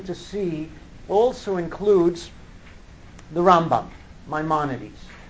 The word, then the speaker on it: Rambam: So the way to remember it is we say Rambam, Maimonides, with the emphasis on the first syllable, and Ramban, with Nachmanides, with the emphasis on the second syllable.